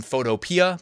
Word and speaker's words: Photopea: Someone said it's Photo P, and I'm like, well, I say Photopea, but I guess you could be right.